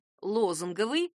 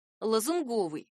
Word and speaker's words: лозунговый: Другая часть прилагательных застряла где-то посередине дороги, например, прилагательное «лозунговый» от слова «лозунг» допускает оба варианта — и «лозунговый», и «лозунговый».